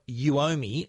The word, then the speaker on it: Uomi: Well, the new smart air pump air pump from Uomi, and that's spelled U O M I. It sounds like you owe me, you owe me money.